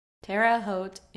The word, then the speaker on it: Terre Haute: I looked it up earlier, but it's like, and I know how to say it. It's just I second guess it because it's spelled weird. Terre Haute, Indiana.